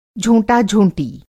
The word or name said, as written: झोंटा-झोंटी